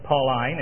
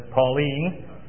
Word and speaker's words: Pauline: I might add that I was instructed many long years ago by my friend, fellow servant, former teacher Charles Ryrie, to consider pronouncing the word Pauline instead of Pauline, in contrast to the feminine name.